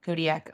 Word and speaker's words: Kodiak: Coast Guard's major air station closest to Russia in Kodiak, Alaska.